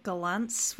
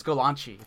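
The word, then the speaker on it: Gollancz: I say it Galance with a flourish, but I've heard Golanx from you. – The Z is a C-H-E sound. It's Golanchi. It's Italian.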